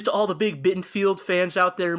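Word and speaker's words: Bittenfeld: Yeah, yeah, no, sorry, that's Bittenfield. Rhymes with Garfield.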